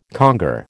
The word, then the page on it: conger: American English